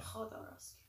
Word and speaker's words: Jodorowsky: Khodorovsky. – Jodorowsky, yeah. – Yeah, well, that's what it is. – Yeah, Khodorovsky. – That's how they say it over there.